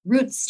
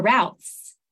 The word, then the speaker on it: routes: Yeah, I think you can say roots or routes, either way, it's fine.